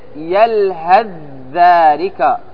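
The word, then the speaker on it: يلهث ذلك: إذا وجد ثاء مع ذال فإنك تضغم، مثل يلهذ ذلك، تضغم الثاء في الذال وتنطق ذالا مشددة، ذالا مشددة.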